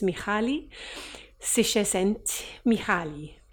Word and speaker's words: Mihaly Csikszentmihalyi: his name is Mihaly Csikszentmihalyi.